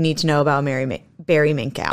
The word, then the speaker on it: Minkow: Let's get more upset about Barry Minkow. – Okay. I'll take your word for it. – That's how they pronounced it in the documentary I watched.